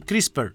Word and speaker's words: CRISPR: Pronuncia-se CRISPR, que significa repetições palindrômicas curtas, agrupadas e regularmente interpassadas.